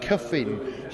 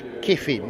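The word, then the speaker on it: Kyffin: I'm going to pronounce it the Welsh way, Cuffin Simpson. His name is Kiffin, that's how he pronounces it.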